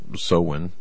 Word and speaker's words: Samhain: called Samhain, or Samhain. It's, you know, that's how it's pronounced, but it's actually spelled differently.